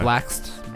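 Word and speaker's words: Blxst: Blaxt, B-L-X-S-T, I believe is how you say it.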